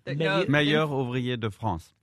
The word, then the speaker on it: Meilleur ouvrier de France: No, M-O-F. – M-O-F. Meilleur ouvrier de France.